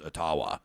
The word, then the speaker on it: Ottawa: He says, until an Algonquin elder reached out to him with an email and said, you know, it actually is pronounced Ottawa.